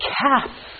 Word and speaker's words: calf: So when you say the word half, L is silent. When you say the word calf, L is silent.